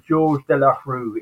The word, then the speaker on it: Georges Delarue: Okay, well, it's actually Georges Delarue.